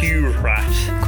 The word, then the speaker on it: curhat: Nope. It's pronounced curhat. It's an Indonesian term.